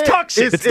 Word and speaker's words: Tucson: T-U-C-S-O-N. – Yep. – And the car is T-U-C-S-O-N, but it's really Tucson. – No. – It's Tucson. – You're wrong. – No, it's Tucson. – You stop lecturing people on something that you're incorrect about. – I'm with you. It's Tuxin.